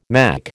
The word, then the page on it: mack: American English